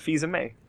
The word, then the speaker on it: Fils-Aimé: Fils-Aimé.